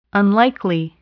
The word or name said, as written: unlikely